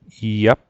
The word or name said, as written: iap